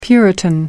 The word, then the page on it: puritan: American English